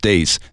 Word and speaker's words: Deace: Because it's spelled D-E-A-C-E, but it's pronounced Dace.